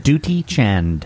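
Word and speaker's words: Dutee Chand: Duty Chand.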